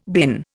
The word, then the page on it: bin: Indian